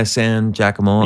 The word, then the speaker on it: San Giacomo: Is that how you say it? Yeah, San Giacomo.